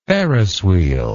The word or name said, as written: ferris wheel